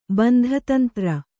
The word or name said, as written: ಬಂಧತಂತ್ರ